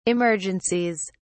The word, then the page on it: emergencies: British English